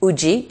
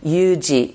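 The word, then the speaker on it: uji (有時): So in this case, uji is one way, yuji is another. And then if you pronounce it in, you know, Japanese, in the native Japanese, it would be arutoki.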